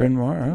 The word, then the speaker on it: Bryn Mawr: It was in an affluent area close to many great schools like, again, I'll it, say it wrong, Bryn Mawr. Is that how you say Bryn Mawr?